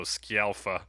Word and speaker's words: Scialfa: Patty Scialfia. – I always forget how to say the name. Is it Scialfia? – Well, there's an I in there, so Scialfa.